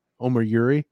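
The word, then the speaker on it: Omoruyi: Omar Yuri, is that what it is? – Yeah.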